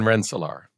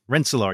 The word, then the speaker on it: Rensselaer: Rensselaer. – Rensselaer, yes.